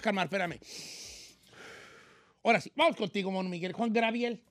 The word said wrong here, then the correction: Juan Gabriel: Juan Gabriel, señor.